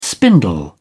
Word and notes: spindle: British English

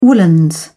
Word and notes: woollens: British English

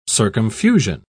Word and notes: circumfusion: British English

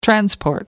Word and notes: transport: American English

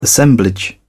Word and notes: assemblage: British English

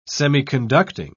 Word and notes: semiconducting: British English

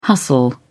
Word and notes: hustle: British English

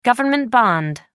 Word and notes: government bond: American English

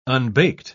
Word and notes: unbaked: British English

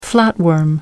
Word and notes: flatworm: American English

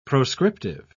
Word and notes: proscriptive: British English

